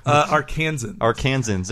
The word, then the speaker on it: Arkansans: I hope we have no Arkansanians listening. How do you say that? – Arkansans. – There we go.